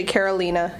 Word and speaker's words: Carolina: But it's actually Carolina. – Carolina, not Carolina. – No, yeah. But don't worry. Everyone does that. It's totally... – It's spelled like Carolina, you would think. It is. Yeah, no, it's Spanish.